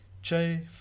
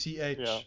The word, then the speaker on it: Che: Che Fihaki. – So it's Che, like Che Guevara. – No. So Che Guevara is Che. This is Chay.